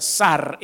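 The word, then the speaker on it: Sar (שר): Y en la Biblia, la palabra príncipe en esa cita bíblica específica tiene una traducción de una palabra hebrea que se llama o se pronuncia Sar, S-A-R, Sar.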